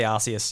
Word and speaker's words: Arceus: Yeah, Arceus or Arceus? How do you say it? – I'll say Arceus, yeah.